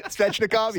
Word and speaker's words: Svechnikov: Svechnikov, yeah.